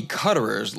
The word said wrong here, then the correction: Cutrer: last name is actually pronounced Cutrer or Coutrer.